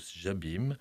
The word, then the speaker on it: Jobim: Antonio Carlos Jobim, qui, pour la petite histoire, est un descendant de français, puisqu'en fait, son nom c'est Jobin, mais bien sûr qu'on le prononce plus maintenant à la française.